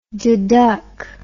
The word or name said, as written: deduck